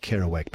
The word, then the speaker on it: Kerouac: It's Kerouac? – It's well done. It's Jack Kerouac. That's a brilliant effort. Good luck. This song will be... This will test you. You've done brilliantly with Razorback and Kerouac.